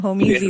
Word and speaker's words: Homeez: Home Easy, yeah. That's how it's pronounced. But it's spelled H-O-M-E-E-Z.